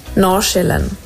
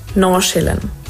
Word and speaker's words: Nordsjælland: Also eigentlich ist es gar nicht so eine Sache, aber man würde es so aussprechen. Nachschillen. Das D sagst du eigentlich nicht und das andere ist so Schä. Nachschillen.